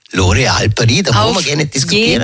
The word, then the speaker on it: L'Oréal Paris: Die Kerstin hat bei L'Oréal Paris in Wien gearbeitet und uns gesagt, bei uns spricht man das so aus mit S.